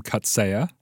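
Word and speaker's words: Coetzee: I've pronounced this many times, so I hope I'm doing it right. The Nobel Prize-winning writer J.M. Kutsaya and a woman named Arabella Kurtz co-wrote a book called The Good Story, Exchanges on Truth, Fiction, and Psychotherapy.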